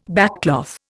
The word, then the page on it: backcloth: Indian